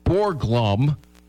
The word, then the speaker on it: Borglum: The sculptor's name is Gutzon Borglum. How do you say it? Borglum.